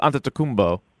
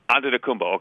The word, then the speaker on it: Antetokounmpo: Sorry, that's just that's the Greek pronunciation. Giannis Antetokounmpo. – Antetokounmpo. Okay. – Yeah. – I got it right.